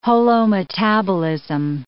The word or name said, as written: holometabolism